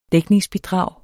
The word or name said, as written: dækningsbidrag